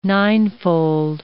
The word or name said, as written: ninefold